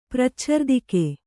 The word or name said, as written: ಪ್ರಚ್ಛರ್ದಿಕೆ